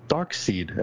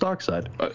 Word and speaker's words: Darkseid: Evil was represented by the hellish planet Apocalypse, with a K, and its overlord, who I used to call Darkseid as a kid, but it's Darkseid.